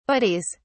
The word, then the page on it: buddies: British English